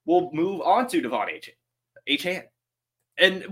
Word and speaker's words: Achane: Because I know it's Achane now, but I still feel like it's Devon given the apostrophe. But anyway, Achane.